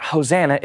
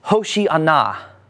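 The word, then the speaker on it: Hoshana: it's not Hosanna, it's Hoshi'ana. And it's translated in Greek as Osana, right? The H is kind of silent.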